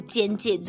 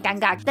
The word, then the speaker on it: 尴尬: 还是不是积非成是？就是古时候的确，它是“ 尴尬”这两个字的确是有被记载出“ 奸介” 这个读音的，只是因为字典里面有解释说。这个读音的确从古至今都很少使用，oh. 所以大多数的人我们都还是念尴尬。